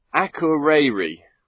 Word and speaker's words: Akureyri: Akureyri.